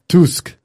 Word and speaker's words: Tusk: Tusk. – Is that how you pronounce it? – Well, that's how they say it in the Fleetwood Mac song.